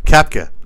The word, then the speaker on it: Koepka: Who he lost to, how do you say his last name? Brooks? – Koepka. – Koepka. Like, okay.